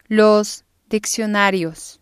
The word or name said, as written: los diccionarios